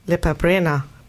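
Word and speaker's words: Lepa Brena: Nämlich Lipa Brena.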